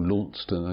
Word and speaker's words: Launceston: South West Peninsula League, West Callington, Callington versus Launceston, or Launceston, as you pronounce it.